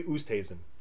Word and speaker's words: Oosthuizen: Louis Oosthuizen. I'll never say his name right. 50-1. – Louis Oosthuizen. – Oosthuizen. Thank you.